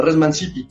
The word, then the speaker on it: res mancipi: Res mancipi es, es la palabra latina, pero bueno, más bien con pronunciación italiana, la res mancipi.